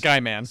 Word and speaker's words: Skyman: We should probably say it correctly, yeah, at least once. It's Skyman.